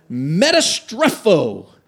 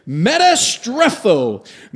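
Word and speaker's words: Metastrepho (μεταστρέφω): Now, this is how we're going to butcher this word together. Metastrepho. Say that with me. Metastrepho.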